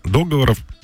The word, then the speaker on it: договоров: Иногда люди говорят договоров, чаще договоров, а правильно договоров.